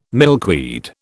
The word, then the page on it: milkweed: British English